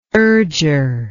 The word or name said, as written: urger